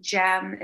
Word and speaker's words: Gem: It's just my name, Jem. It's spelled G-E-M, like gemstone, Rosenberg, at .com.